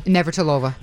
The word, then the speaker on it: Navratilova: I can't say her name. – Navratilova. – That's the one.